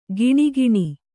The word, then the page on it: ಘಿಣಿಘಿಣಿ: ghiṇighiṇi